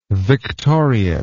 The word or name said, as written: victoriate